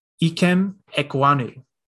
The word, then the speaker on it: Ikem Ekwonu: Ikem Ekwonu. – Nice. Close that is how you say it.